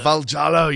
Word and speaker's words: Valjalo: Is it Valjalo? – It's actually Valjalo. – Valjalo, you see? – Yeah, so you pronounce everything in the exact way that it's spelled. You pronounce every single letter.